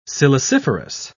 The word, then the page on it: siliciferous: British English